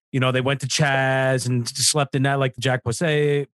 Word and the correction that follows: Posobiec: Posobiec. – I can never say his name. Posobiec.